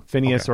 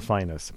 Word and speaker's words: Phineas: I've heard both are acceptable, Phineas or Phineas. Apparently, if you're in Memphis, it's Phineas.